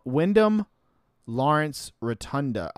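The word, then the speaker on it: Windham Lawrence Rotunda: Wyndham Lawrence Rotunda.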